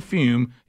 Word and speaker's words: Fum: Now, when I say Fume, even though I'm saying it this way, it's spelled F-U-M.